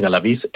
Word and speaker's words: Galaviz: Sí, antes que nada, una corrección. Es Juan Manuel Galaviz Herrera. – Disculpa, Galaviz. Digo la pronunciación. – Galaviz, sí. – Galaviz, sí. Bien.